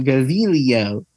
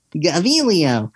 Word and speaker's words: Gaviglio: It actually is supposed to roll off the tongue. Gaviglio. Gaviglio.